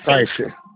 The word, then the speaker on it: Caixa: Eu não vou saber pronunciar esse nome, KF. – Caixa, caixa.